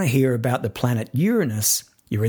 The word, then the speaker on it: Uranus: And in case you think I'm being prudish with the way I say Uranus, I'm not. Uranus is the scientifically recognized pronunciation of this planet. Something to do with it being from Latin.